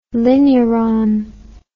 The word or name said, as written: linuron